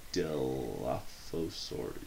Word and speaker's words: Dilophosaurus: Dilophosaurus? Is that how you say it? – Dilophosaurus. Yeah, sure.